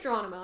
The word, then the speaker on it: Geronimo: Geronimo. Am I saying this wrong? – Geronimo, an American legend. – It is Geronimo.